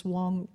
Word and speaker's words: Wong: you know, I mean, of course, you know, there's always the name calling because Wong rhymes with ping pong and King Kong.